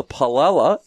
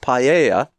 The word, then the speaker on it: paella: We have the Palella instead of Paella.